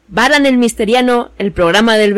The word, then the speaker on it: Varan: El monstruo de hoy se dice Baran, pero se escribe Baran con B de burro.